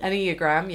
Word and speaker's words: Enneagram: So look, wait, we should explain the Enneagram. Is that how you pronounce it? – Enneagram, yeah.